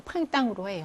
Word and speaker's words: printemps: 프랑스어는 끝에 자음이 발음되지 않는 경우가 많기 때문에 이렇게 쓸 때는 S로 쓰지만 발음은 팡땅으로 해요. 그래서 발음할 때는 S가 있거나 없거나 발음이 똑같기 때문에 이런 것들도 이제 철자에서 조심하셔야 되죠.